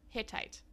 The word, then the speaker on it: Hittite: Hittite.